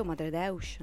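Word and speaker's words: Madredeus: Io non la pronuncio portoghese, come si dice bene. – Ma credo Madre Deus. – Ecco, perfetto, tu hai pronunciato perfettamente, dimmi.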